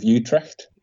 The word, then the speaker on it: Utrecht: I found a nice little spot on the floodplains just south of Utrecht. – Good pronunciation there.